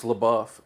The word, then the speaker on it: LaBeouf: But I swear everybody called this kid Shia LaBeouf. And then once Transformers come up, they're like, it's LaBeouf. And I'm like, but look how it's spelled. Like you would pronounce that, wouldn't you, LaBeouf? Oh, no, it's LaBeouf. So I stand corrected. His name is Shia LaBeouf.